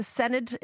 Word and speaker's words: Senedd: Am I pronouncing this right? The Senedd? Is that right, Adam? Good morning to you. – That's absolutely perfect.